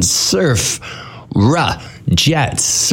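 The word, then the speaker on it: Surfrajets: I'm pronouncing it for DJ Townsend, Surfra Jets. Surfra Jets. Because apparently I mispronounce it all the time.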